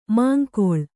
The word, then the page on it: ಮುಂಗಾಳು: Kannada